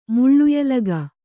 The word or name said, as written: ಮುಳ್ಳುಯಲಗ